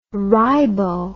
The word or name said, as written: ribo